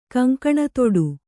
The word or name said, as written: ಕಂಕಣತೊಡು